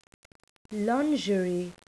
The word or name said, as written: lingerie